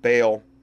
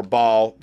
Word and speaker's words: Baal: It's called The Human Temple of Baal, or Baal, depending on how you want to pronounce it.